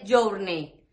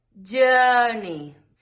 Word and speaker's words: journey: Fijaos que incluso tenemos algunas palabras que van con O, U, R y se pronuncian A. Uh. Por ejemplo, journey. No dices journey, es journey. Y luego tenemos palabras que se escriben con I, A, R.